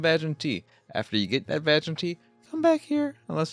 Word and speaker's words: Vagante: This episode is all about Vagante. I know what you're thinking. It's pronounced Va-Gan-Te. I assure you it is not pronounced Va-Gan-Te.